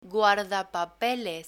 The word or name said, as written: guardapapeles